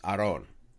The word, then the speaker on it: Aaron: Like, my first name technically should be pronounced Aron. But even my family, mm-hmm. like, they just gave up. They're like, we're tired of correcting people. – It's just Aaron.